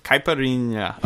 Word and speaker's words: caipirinha: Caipirinha. – Oh, so you're saying? Oh, you pronounce it so well.